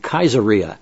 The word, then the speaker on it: Caesarea: Well, Caesarea, the city, was also spelled with a K, so it was actually pronounced in Greek Caesarea, because it's named after the Kaiser, Caesar, same term in Greek.